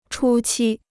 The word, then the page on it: 初期: chū qī